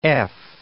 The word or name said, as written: EF